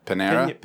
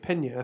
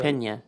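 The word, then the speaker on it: Peña: Panera? – Pena. I think you're right. – Pena. Yeah. You just roll the in like jalapeno.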